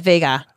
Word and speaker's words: Vhagar: No, we call that Vhagar. – Yeah, Vhagar. You got to say it right.